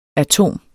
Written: atom

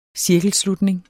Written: cirkelslutning

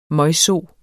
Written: møgso

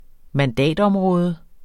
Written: mandatområde

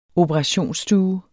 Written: operationsstue

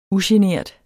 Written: ugenert

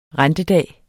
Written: rentedag

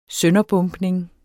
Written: sønderbombning

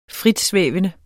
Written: fritsvævende

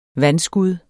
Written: vanskud

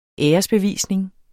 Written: æresbevisning

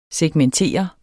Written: segmentere

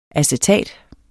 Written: acetat

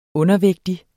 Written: undervægtig

